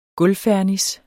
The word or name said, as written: gulvfernis